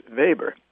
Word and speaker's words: Weber: We would say Weber, but the academic community does use the German pronunciation and say Max Weber.